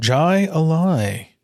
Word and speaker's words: Jai Alai: Jai Alai, I think is how you pronounce it. – Jai Alai.